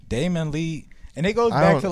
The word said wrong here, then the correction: Damian Lee: Because I don't even know if you pronounce his name right. – Damian Lee. – Damian Lee. – It's Damian Lee.